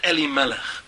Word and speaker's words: Elimelech: What does the name Elimelech, how does it break down in syllables? Two syllables. The first syllable, Eli, or Eli, to me. And the second syllable, or second word, Melech, king. To me is the king. Eli, Melech.